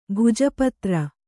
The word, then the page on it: ಭೋಜಪತ್ರ: bhōja patra